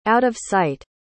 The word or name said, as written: out-of-sight